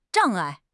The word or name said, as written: 障碍